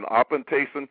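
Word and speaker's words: apantasin (ἀπάντησιν): It's spelled A-P-A-N-T-E-S-E-S or S-I-N, apantasin.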